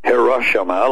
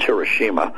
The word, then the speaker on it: Hiroshima: The one that we dropped on Hiroshima, not Hiroshima, like these dumbass liberals want to pronounce it, it's Hiroshima.